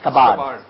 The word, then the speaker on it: scabbard: S-C-A-B-B-A-R-D. It is, how is it pronounced? Kabar.